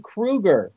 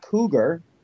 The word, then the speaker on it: Krueger: Oh, but by the way, you mispronounced his name. It's Freddy Krueger, not Cougar.